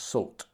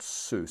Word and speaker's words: Soult: By the way, someone advised me that Sue is pronounced Salt, not Sue, so apologies for that in the last episode.